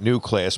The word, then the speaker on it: Neue: And that's the car that I'll pronounce it new class, but it was the Neue class.